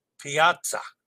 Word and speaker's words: Piazza: Pronounced like pizza with A in the middle. Piazza.